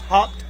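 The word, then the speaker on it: Haupt: 52, Aaron Hoppe, or Hopt, H-O-P-P-T, H-A-U-P-T, but it's pronounced Hopt, bingo.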